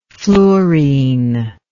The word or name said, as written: fluorene